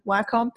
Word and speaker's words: Wacom: So you're talking about Nikon um, and Adobe, Wacom, although people pronounce it Wacom, but it's actually Wacom.